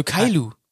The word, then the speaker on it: Caillou: Das schreibt man C-A-I-L-L-O-U. Alter. Also Kailu.